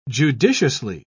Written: judiciously

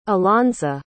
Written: alonsa

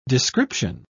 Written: description

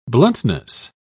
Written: bluntness

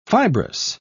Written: fibrous

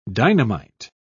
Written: dynamite

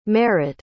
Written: marat

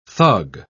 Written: thug

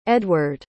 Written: edward

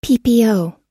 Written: ppo

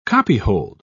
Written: copyhold

